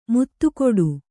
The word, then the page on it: ಮುತ್ತುಕೊಡು: muttu koḍu